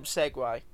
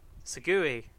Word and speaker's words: segue: It's not. But it's Segue. Segue. When you mean it like that, it's Segway when you're talking about the vehicle, the Segway.